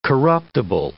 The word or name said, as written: corruptible